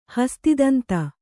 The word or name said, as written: ಹಸ್ತಿದಂತ